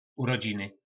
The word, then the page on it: Urodziny: po nasimu